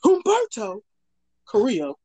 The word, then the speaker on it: Humberto Carrillo: Humberto Carrillo.